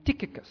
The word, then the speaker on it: Tychicus: First, we have a man named Tychicus. C-H sounds like a K. It's a Greek name, and therefore, most likely, Tychicus was a Greek man from the city of Ephesus on the west coast of Asia Minor, or current Turkey.